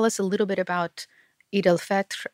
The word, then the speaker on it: Eid al-Fitr: So the holiday that Ramadan ends with is called Eid al-Fitr. That's how we'll say it in Urdu.